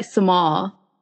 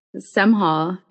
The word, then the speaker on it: Semhal: And then for me, they'll be like Semhal. And I'm like, first of all, there's an H in my name, Semhal.